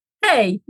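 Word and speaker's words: hej: Hey, jak się masz? – Hey, jak się masz? – Sí, sí, hey, con H la pronuncias. Tienes que pronunciar la H.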